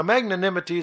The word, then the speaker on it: magnanimity: Magnanimity is M-A-G, and then na, N-A, and then nim, N-I-M, and then I-T-Y. So magnanimity.